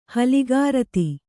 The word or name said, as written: ಹಲಿಗಾರತಿ